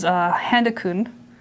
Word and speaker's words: Handakun: So my first one is Handakun. – Handakun. – Yeah. It's spelled like panda, but it starts with an H.